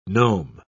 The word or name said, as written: nome